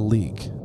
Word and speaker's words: Leyak: It's called the Leek. It's spelled L-E-Y-A-K, but it's pronounced leek.